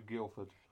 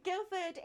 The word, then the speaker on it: Guildford: So Guildford is a cathedral city in the southeast of England that actually has a silent D in the name, because why should we spell something phonetically? – Yep.